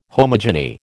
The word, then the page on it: homogeny: American English